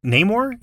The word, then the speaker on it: Namor: And I'm excited for Namor. Is that how you say it? – Yes. – Namor? – Yes.